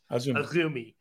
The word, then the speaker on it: AZM: As you can see on the screen, Azumi's name is spelled A-Z-M. – Yes. – A-Z-M is how it looks every time you see it written out, but it is, in fact, Azumi.